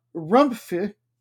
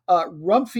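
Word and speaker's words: Rumphi: It's R-U-M-P-H-I, United. And the way that I read it is that you're supposed to pronounce the P as a P, but then the P-H also as just a little bit in an F sound, and I cannot seem to do it.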